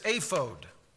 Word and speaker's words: ephod: Now, I mentioned this before that we typically call it, in fact, let's just find out how you would pronounce that. Ephod. That's the way I pronounce it. But I went to the Hebrew and I thought, well, I'm just going to do it with the vowel pointings in Hebrew. And literally, ephod or ephod, when you say ephod, you're speaking Hebrew because this is a transliteration of it.